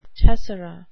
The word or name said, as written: tessera